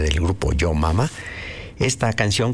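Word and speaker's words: Jo Mama: En esta agrupación llamada Yo Mama, así se escribe, J-O Mama, Yo Mama, formados en Los Ángeles, California, en 1970, grabaron dos discos, uno en el 70, otro en el 71.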